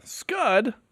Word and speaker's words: Scud: Scud, that rhymes with stud, you know, like stud.